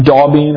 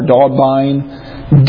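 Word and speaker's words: d'Aubigné: Then Merle, M-E-R-L-E. Here's the weird one. Daubin or Daubine. D, apostrophe, A, U, B, I, G, N, E. It's a French name, I think. Merle Daubin or Daubine. Looks like Daubin.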